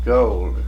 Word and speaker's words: goal: Have you ever heard the word ghoul? Or the pronunciation ghoul? – No, I don't believe I ever did. – I picked that up in the northern part of the state. – So. – It rather surprised me. I didn't expect it. It's that's a common New England pronunciation.